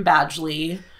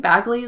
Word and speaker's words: Badgley: It's Penn Badgley. Honestly, I think Bagley is better.